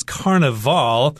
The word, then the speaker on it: Carnaval: Right, and this is also sometimes pronounced as Carnaval. If you're using Portuguese, a lot of people pronounce it that way.